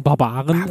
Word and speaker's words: Barbaren: Und ich glaube auch in diesem Zuge ist die Serie Barbaren, wie gesagt, man muss es so ein bisschen, bisschen tiefer aussprechen, Barbaren ähm, mhm.